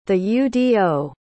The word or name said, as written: theudo